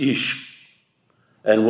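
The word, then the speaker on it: isha (אִשָּׁה): Note, in Hebrew, man is ish and woman is isha. Ish is one syllable, Isha two syllables.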